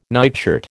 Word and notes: nightshirt: American English

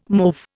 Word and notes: mauve: Indian

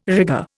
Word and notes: rigour: Indian

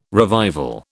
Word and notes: revival: British English

